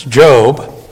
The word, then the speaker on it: Job: Okay, we've come to the book of Job, and that is the correct pronunciation in English. I heard about some brand new Christian that knew nothing about the Bible, and he says, what is this book of Job? It's Job. One of the Puritans, Joseph Carroll, preached for 20 years on it, and they published his sermons in 13 massive volumes.